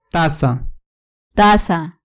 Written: taza

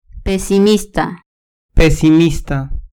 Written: pesimista

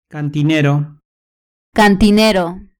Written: cantinero

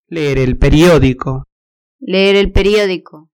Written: leer el periodico

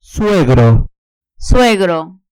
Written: suegro

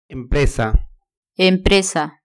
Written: empresa

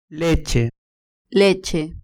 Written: leche

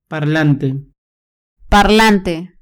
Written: parlante

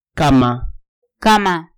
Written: cama

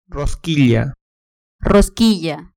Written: rosquilla

